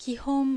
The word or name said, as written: Kihon